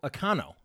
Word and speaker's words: Okonkwo: So Okonkwo. – Okonkwo. – Okay. – Yep. – So the second K is silent. – The second K is silent, I guess.